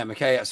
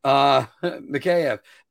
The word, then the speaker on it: Mikheyev: Ilya Mikheilov that came over from the Toronto Maple Leafs. Yeah, Mikheyev. Sorry, I added an extra syllable. Mikheyev. But also the guy from Russia that they got, whose name is slipping my mind right now.